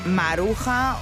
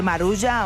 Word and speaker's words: Maruja: tú eliges si eres de los que les llama Maruja o de los que compramos un poco esa pronunciación anglosajona de la J para decir Marulla, aunque en realidad Maruja es el nombre de origen.